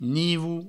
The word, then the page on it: nivo: nivou